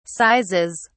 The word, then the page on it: sizes: British English